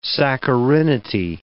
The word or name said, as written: saccharinity